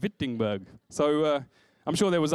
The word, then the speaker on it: Wittenberg: Now, the best thing about the place Wittenberg is you spell it with a W, Wittenberg. But then when you say it with a V, Wittenberg, you just sound German straight away.